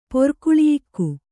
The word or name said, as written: ಪೋರ್ಕುಳಿಯಿಕ್ಕು